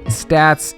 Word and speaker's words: Staats: Statz, S-T-A-A-T-S.